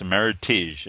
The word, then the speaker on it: Meritage: People may have heard of this – spelled Meritage. It's pronounced Meritage. It rhymes with heritage.